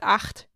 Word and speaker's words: acht: And the thing here is that actually these two words that he gives, the two German words, have two different pronunciations. So it's echt, but acht. And the CHT after E is pronounced differently in German than after A.